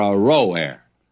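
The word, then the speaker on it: Aroer: Now, I probably ought to go back and give you another pronunciation of that. The cities of Ar-O-Air, Ar-O-Air, are forsaken. And let's settle for Ar-O-Air.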